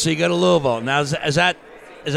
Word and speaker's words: Louisville: Louisville, yeah.